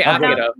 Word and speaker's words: Akira: A lot of people say Akira, but I think it is Akira, no? – Yeah. – Akira. – Right? – It's definitely Akira.